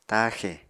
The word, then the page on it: taaxè: Apatani